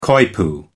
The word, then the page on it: coypu: American English